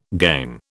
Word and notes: gangue: British English

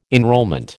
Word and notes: enrolment: American English

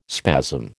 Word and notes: spasm: American English

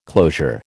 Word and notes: closure: American English

